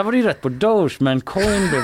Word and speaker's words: Doge: Eh, och eh, sa att jag tror att jag fattar vad du menar, jag är med dig men att jag tyckte att du uttalade Doge fel. – Mm. Den här alltså hunden som är ett meme, känner du till den? Det Shiba-hunden. – En shiba-hund som är liksom eh, ett meme-format som är ganska stort. Liksom. Eh, men eh, jag trodde att man uttalade dog Mm. – Men det visade sig att det är Doge då.